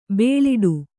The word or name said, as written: ಬುಲೆಟ್ಟು